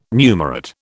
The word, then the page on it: numerate: British English